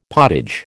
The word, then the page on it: potage: American English